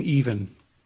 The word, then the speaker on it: Evan: Yeah, Evan pronounces his name Even.